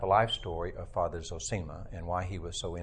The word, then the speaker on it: Zosima: And the person that helps Allelusa deal with these issues is Father Zosima, or Zosima. Being from Texas, I'm limited in pronouncing Russian, but I've always said Zosima. Some people say Zosima,